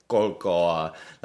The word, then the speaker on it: COLCOA: I also like it how the director of it would always pronounce it. Colcoa, like it's a word unto itself.